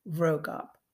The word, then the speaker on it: Vroegop: And his name is spelled V-R-O-E-G-O-P, Vrogop.